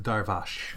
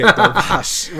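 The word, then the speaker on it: Darvas: She's Hungarian. I have it on good authority. It's pronounced Darvash. – Oh, okay. Darvash.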